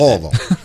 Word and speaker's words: Louisville: Josh on our text line says, as a Louisville native and hater, say it like LOL, but quickly, Loserville is also accepted. – Louisville.